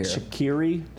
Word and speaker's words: Shaqiri: Shaqiri, did I say that right? – Yeah. – Yeah.